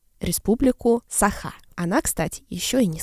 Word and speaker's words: Саха: Собственно, да, ударение должно было быть республика Саха, а не республика Саха.